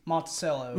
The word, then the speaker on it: Monticello: It's Monticello.